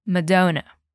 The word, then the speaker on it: Madonna: Ele cantou Madonna. Olha só como a pronúncia é diferente em português do inglês. Repete a pronúncia do inglês comigo. Repeat after me. Madonna.